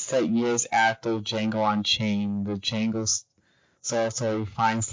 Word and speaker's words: Django: Yeah, he told me the D is silent, so I have to stop saying that. It's Django. it's Django slash Zorro.